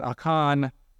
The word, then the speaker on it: Achan: You may have heard his name pronounced Achan, but Achan is closer to the Hebrew pronunciation, so I'll use that one.